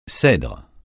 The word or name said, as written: cèdre